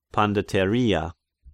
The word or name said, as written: Pandateria